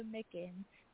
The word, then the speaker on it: Mickens: It's Nayjah Mickens.